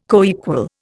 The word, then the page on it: coequal: Indian